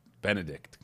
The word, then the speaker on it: Benedict: That's it, how you say it. Benedict.